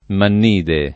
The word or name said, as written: mannide